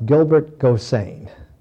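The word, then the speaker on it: Gilbert Gosseyn: The protagonist is Dutch, and his name is pronounced Gilbert Gosain.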